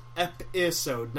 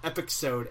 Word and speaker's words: episode: Pronounced episode, not epixode, as I know you have just pronounced it.